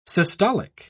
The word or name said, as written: systolic